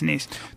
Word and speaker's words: Nice: Nice. – Yes, Nice.